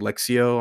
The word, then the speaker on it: Lectio: Um, I don't know why it's pronounced that way. By the way, it's like spelled like L E. What? How do you spell it? It's like L E C T I O or something like that. But it's called like Lexio.